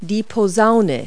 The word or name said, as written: trombone